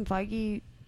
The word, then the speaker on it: Feige: Oh, Feige. I'm sorry. Okay. – Feige. – Sorry.